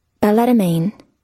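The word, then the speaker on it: balletomane: Ballettomane. – Ballettomane? They actually pronounce it? – Wait, one more time? – Ballettomane. – Well, they're actually pronouncing the T. Ballettomane.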